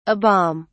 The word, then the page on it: a bomb: British English